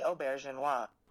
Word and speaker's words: Auberjonois: Odo was played by René Auberjonois.